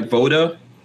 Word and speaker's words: VODA: So Lucien, tell us who you are and what is, am I saying it right, VODA Consulting? – Yeah, don't worry about it. It's all good. Everybody already know what it is. You can see the V, the O, the D, the A.